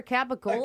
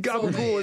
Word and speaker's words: capicola: I don't even know what was the answer for a capicola? – A capicola, bro. I don't know. You gotta know your Italian meat. – Gabagool is how they say it with an Italian accent.